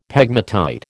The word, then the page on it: pegmatite: American English